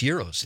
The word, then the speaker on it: gyros: Moved back and he said, let's get gyros because there was a new place in Marinette. And I said, it's pronounced euros.